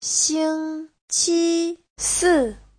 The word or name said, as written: xing1qi1si4